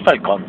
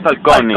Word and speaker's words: Falcone: Pero hay que saber, no es Falcón. – Es Falcone. – Falcone. – Sí, es Falcone, sí, porque sí, es italiano.